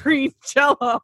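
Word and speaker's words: Green Jellÿ: And said, even though their name is Green Jelly, it's still pronounced Green Jello.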